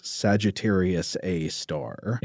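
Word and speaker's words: Sagittarius A*: This compact radio source together is known as Sagittarius A star. – And if you've seen this printed or typed, it is Sagittarius A asterisk. The asterisk stands for star. – Right. It's pronounced star,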